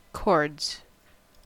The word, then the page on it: cords: American English